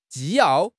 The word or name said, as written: 纪要